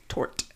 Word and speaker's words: tort: Well, it's tort. – Oh, tort. – Like tart, but with a O.